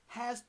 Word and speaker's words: Huh: The second pair, or second couple, this couple has to do with time. The African ancestors taught us of time. Ha, spelled H-U-H. Ha, and let me go back and spell Nun. Nun is spelled N-U-N, sacred divine masculine.